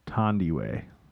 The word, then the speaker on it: Thandiwe: It's spelled Thandiwe. – But now Newton is correcting the error, telling British Vogue that it is Thondwe. – Tandy Way.